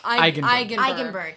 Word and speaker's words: Eigenberg: Like, from day one, David Engelbright, that's how you say his name? Or Engelbert. – Eigenberg. – Thank yeah.